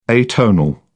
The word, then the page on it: atonal: British English